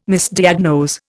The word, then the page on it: Misdiagnose: Indian